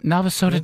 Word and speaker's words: Navasota: Is it Navasota, Texas? Is that how it's pronounced? – That's correct. – Yep, Navasota, Texas.